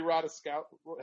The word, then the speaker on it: Ratajkowski: I could have said Emily Ratajkowski.